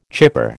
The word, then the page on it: chipper: American English